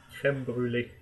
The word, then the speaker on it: crème brûlée: And it's okay, because personally, I like saying crème brûlée more than like saying the right pronunciation for it. – But what's the right pronunciation? – Crème brûlée.